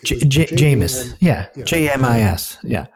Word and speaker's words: Jameis: Is pretty clear, but, and the latest, I mean, a lot's happened, but the latest is obviously the biggest, the test with, Jameson. No. How do you say his name? – Jameis, I think. – Jameis, not Jameson. Jameson's a drink.